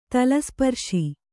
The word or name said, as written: ತಲಸ್ಪರ್ಶಿ